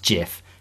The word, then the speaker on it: GIF: It is a soft G, pronounced GIF.